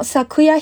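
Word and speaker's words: 桜: 木花开耶基，我们读作 konohana no sakura hime。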